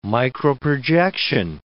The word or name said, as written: microprojection